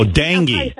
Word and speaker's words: dengue: And everyone with her came down with something called Dungy Fever. – Dungy Fever? – Dungy, I think. – Oh, dengue. You mean dengue fever.